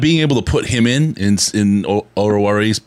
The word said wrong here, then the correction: Oruwariye: God, I was laughing. – Oruwariye. – I know how to say it. Right? I know how to say it. My mouth just doesn't move that way.